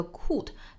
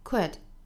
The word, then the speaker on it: could: I wish, 后面 I could be Kit 可能小的时候在学这个单词的时候学成了一个 could 这样的发音，其实是不对的。那么其实它是一个短元音 w 的发音 could could，